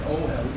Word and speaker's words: ohel: It's the only one that you actually pronounce it two syllables before the end. Ohel. Ohelo. It's ohelo. – Ohel, okay. – If it's three syllables, you just go back one. But here you go back two.